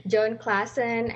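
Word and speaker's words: Jon Klassen: I guess my favorite lately is John Klassen. – Mm-hmm. – I don't know if I pronounce his name correctly. – That would be how I would pronounce it. – Yeah. John Klassen.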